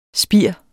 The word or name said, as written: spir